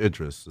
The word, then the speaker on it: Idris: Me and Idris, it's actually Idris is the way he pronounces it.